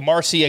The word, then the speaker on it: Marsee: 3 came from Jacob Marcy. He stole 3 against Kevin Parada. So he's been a monster out here. – All right, Jacob Marcy again.